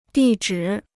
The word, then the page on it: 地址: dì zhǐ